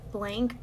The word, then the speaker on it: blanc: I Some of my favorite shades are blank, but it's spelled like the French way, like B-L-A-N-C.